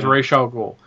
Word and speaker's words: Ra's al Ghul: It was Ra's al Ghul. I wouldn't put an H there looking at it because, you know, it's R-A-S.